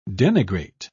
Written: denigrate

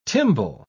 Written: tymbal

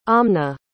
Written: amna

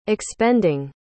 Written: expending

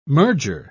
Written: merger

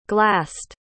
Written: glassed

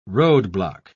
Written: roadblock

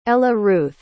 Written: ellaruth

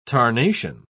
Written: tarnation

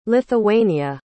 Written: lithuania

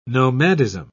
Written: nomadism

